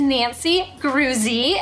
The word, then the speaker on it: Groozy: And this week, our guest is Nancy Groozy. It rhymes with groovy.